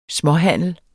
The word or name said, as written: småhandel